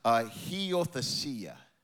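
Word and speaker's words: huiothesia: You say it like this: heothesia. Heothesia is how you say this word.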